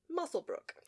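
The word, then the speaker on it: Muswellbrook: She attended Moswellbrook? – In the documentary, which is literally almost the only thing I remember from this, because I watched it like a week ago, they pronounced it Musselbrook.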